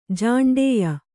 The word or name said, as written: ಜಾಂಡೇಯ